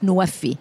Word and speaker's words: Nuwafi: And Nexus of Water, Food and Energy is pronounced Nuwafi. Nuwafi in Arabic means fulfilling pledges.